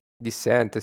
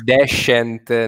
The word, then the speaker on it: Descent: Dissent, sì, c'è stata tutta una storia lì su, in chat del blog che Descent non si può dire, allora bisogna dire Dissent.